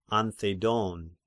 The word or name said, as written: Anthedon